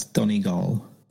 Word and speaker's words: Donegal: Dungle. Londonderry. – Dongle? No, that's Donegal.